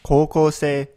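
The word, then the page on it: koukousei: Japanese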